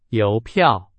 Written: 邮票

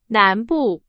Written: 南部

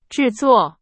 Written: 制作

